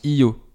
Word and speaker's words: EO: Le nom Eo dans le film se prononce Io, comme on essaie de le faire euh, depuis le début du podcast.